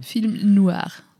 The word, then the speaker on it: film noir: das muss man auch französisch aussprechen. – Film noir.